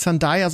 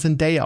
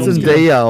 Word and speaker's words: Zendaya: Und übrigens, ich habe gelernt, sie wird nicht Zendaya, sondern Zendaya ausgesprochen. – Ja, die wird Zendaya ausgesprochen, ja.